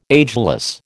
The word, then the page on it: ageless: American English